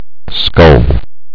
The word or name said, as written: skull